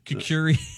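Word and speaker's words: kukri: What's a coochie sword? – I might have said it wrong. I'm pretty sure it's kookie. – Kukuri. – Kukuri? Yeah, not coochie.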